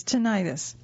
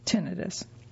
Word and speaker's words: tinnitus: By the way, you may have also heard this referenced as tinnitus. Both pronunciations are correct. However, most medical professionals will use tinnitus.